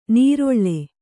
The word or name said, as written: ನೀರೊಳ್ಳೆ